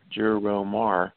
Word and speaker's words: Gerromar: There was a interestingly, back in the 1970s, a company by the name of Juromar, I don't know if you've ever heard of that. It's a pretty important legal case. And it's spelled GERROMAR, Juromar.